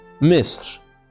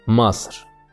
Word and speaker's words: مصر: احنا بقى كمصريين بنسمي بلدنا مصر بس باللغه العربيه الفصحى اسمها مصر فلما هتغني النشيد الوطني في المدرسة بكرة خد بالك وانطق اسم بلدك صح وقول مصر مش مصر